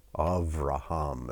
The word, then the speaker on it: Avraham: Avraham is how it's pronounced in Hebrew.